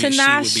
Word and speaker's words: Tinashe: I would put Pharrell and Tinashe.